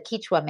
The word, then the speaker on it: Quechua: who was a Quechua man. And that's how they pronounce it there. He said, we're Quechua.